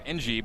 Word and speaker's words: Engie: So, PA announcer saying Skylar Engie, but my pronunciation guide says Engie with a hard G, so I went hard G on that one. It's Skylar Engie.